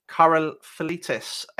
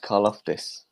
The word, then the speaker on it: Karlaftis: Number 7, George Karlaftis, edge from Purdue. That's the first one I've butchered there. – I think that's pronounced Karlaftis.